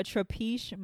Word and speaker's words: Trapiche: So today we're drinking on a Trapiche Malbec. Did I say it right? – Yes, you did.